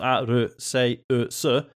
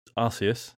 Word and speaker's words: Arceus: Hakon says, basing this off the katakana of the Japanese version of the title, which is pronounced Aru-se-u-su, i.e. Arceus, if he wanted you to say it the other way, they'd use a syllable with a K sound in it, which is, yeah, fair point.